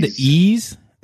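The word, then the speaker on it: Ys: It's said E's, but it's spelled Y apostrophe S.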